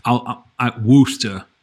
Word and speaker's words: Worcester: So there's a brewery in Mass in or near a city that I would say, Worcester, I think is how you would say it. – Not how it's spelled, but that's how you say it. Yep. – Precisely. If you're reading it on a map, you're already not from there.